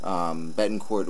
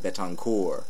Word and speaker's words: Betancourt: Betancourt, which I always pronounce with the T, but announcers seem to think he's French and they end up calling him Betancourt.